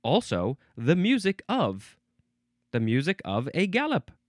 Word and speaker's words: galop: It is spelled G-A-L-O-P, so it looks like gallop or galop, but it is, well, gallop.